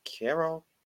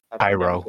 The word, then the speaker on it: Cairo: No, it's Carol. Carol, I don't know how to pronounce that. – Cairo.